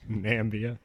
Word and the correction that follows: Namibia: Not really for this one, no. It's pretty much all in Namibia. – Weird. – Namibia. – That's how it's pronounced.